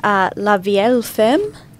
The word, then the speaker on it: La Vieille Femme: Yeah, there's a $18 one here, La Vieille Femme. – Oh, yeah, nice pronunciation. It's French. – It is French.